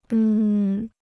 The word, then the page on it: 嗯: en